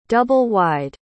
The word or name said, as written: double-wide